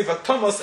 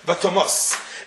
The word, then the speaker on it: Vatomas: so from the fact that the Tam is in the Tof, we know we say Vatomas and not Vatomos.